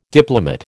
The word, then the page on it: diplomate: American English